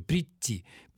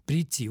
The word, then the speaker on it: прийти: А когда было «придти», то понятно, что это «д» никто никогда не произносил. Там, конечно, на месте «д» звучал «т», просто там оказывались рядом два «т», они произносились как долгие гласные «прийти», «прийти», вот как это звучало.